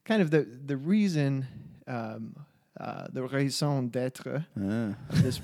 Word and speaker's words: raison d'être: is that how you actually say it like that? – Raison d'être. – D'être. – Yeah. Yeah. Raison d'être.